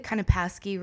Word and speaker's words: Kunopaski: Mostly the Kunopaski really throws them off. It's a lot of syllables. It looks a bit intimidating, but it's literally pronounced exactly how it's written.